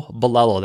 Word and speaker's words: Bilello: And so I'll include a link to that in the notes for this first episode, but it's just Hello Bolelo. That's the name of my business. I candidly created that because I wanted an easy way for people to remember how to pronounce my last name.